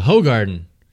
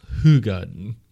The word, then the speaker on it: Hoegaarden: "I like a Hoogarden," and I was I was quickly corrected. Hoogarden it's pronounced Hoogarden.